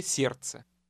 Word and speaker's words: сердце: The Russian D in the second word is silent. – Собачье сердце.